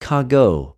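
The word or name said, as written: κἀγω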